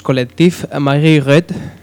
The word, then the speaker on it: Collectif Marguerite: Vamos a pinchar a los Collectif Marguerite. ¿Le he pronunciado bien? Tú que eres conocedor de la lengua francesa. Sí. Muchas gracias. Vamos a escuchar a este grupo, Collectif Marguerite,